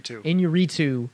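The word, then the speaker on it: Iñárritu: Inuritu.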